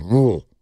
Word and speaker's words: Louisville: That's one of those words that people in California think has three syllables, and it actually has one. – Louisville. – Louisville. Or Louisville. – Louisville. – Yeah. If you live out there, it's just Louisville. You just make a noise.